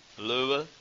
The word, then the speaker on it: Louisville: As soon as you get in and you've settled in, and they say, this flight is going to Louisville, the way you say it correctly, which is Louisville, Louisville, whatever.